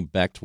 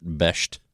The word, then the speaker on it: Becht: So I'm going to ask Anthony Becht why he doesn't pronounce it Besht. I feel like that's what it should be. – No, I don't think it should be that. – Really? – Yeah. Every time I've seen that spelling, E-C-H-T, it's ect.